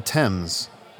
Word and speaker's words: Thames: The Thames, I believe is how you pronounce that.